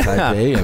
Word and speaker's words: Taipei: Am I saying that right? – Yeah. – Taipei.